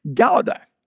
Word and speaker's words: Gouda: Now, in South Africa, this type of cheese is pronounced Gouda. Gouda.